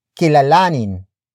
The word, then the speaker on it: kilalanin: There are even specific root words that use the nin suffix instead of hin to turn into an object focused verb. For example, kilalanin, kilalanin, kilalanin.